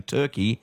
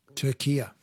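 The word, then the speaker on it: Türkiye: But in yesterday's Socceroos update, she kept saying Turkey and not Turkia.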